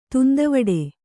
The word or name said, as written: ತುಂದವಡೆ